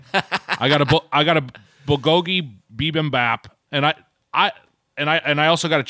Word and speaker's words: bibimbap: Is it bibimbap? Is that how you say it? – That's close enough for comfort, I think.